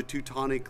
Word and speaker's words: Teutonic: the Teutonic languages, Teutonic cuisines, because Germany wasn't a unified country, and you have Switzerland and Austria, and... So anyway, yeah, it's kind of cool, and it rhymes with chronic.